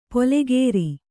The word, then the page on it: ಪೊಲೆಗೇರಿ: polegēri